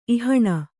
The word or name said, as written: ಇಹಣ